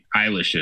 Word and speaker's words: Eilish: And you all know who Billie Eilish is, right? That's how you say it. I'm not a fan of her. So it's Eilish, right? – Yep. – Billie Eilish.